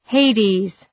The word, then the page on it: Hades: ‘heıdız